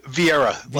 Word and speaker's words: Vieira: Uh, Vieira, like Meredith Vieira.